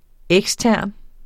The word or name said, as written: ekstern